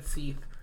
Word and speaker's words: Seath: Which, last time I pointed out that it's spelled S-E-A-T-H. – Seath. – Seath. In case anyone wants to look it up, it is Seath Jackson.